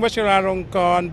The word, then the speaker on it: Vajiralongkorn: King Vajiralongkorn Badintharate Payapalankun.